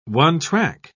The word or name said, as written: one-track